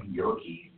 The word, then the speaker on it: Gericke: Shane Yerke, G-E-R-I-C-K-E.